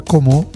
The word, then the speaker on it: Como: Senza l'accento finale, semplicemente sarebbe Como.